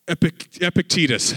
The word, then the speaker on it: Epictetus: This Greek philosopher, Epictetus,